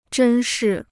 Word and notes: 珍视: zhēn shì

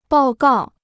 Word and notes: 报告: bào gào

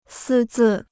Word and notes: 私自: sī zì

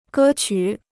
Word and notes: 歌曲: gē qǔ

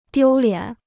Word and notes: 丢脸: diū liǎn

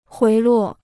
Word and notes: 回落: huí luò

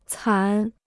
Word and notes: 惨: cǎn

